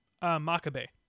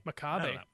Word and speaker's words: Makabe: Makabe. – Right, but I hear like other people Makabe. – Makabe. It's almost like macabre.